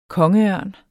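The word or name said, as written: KONGEØRN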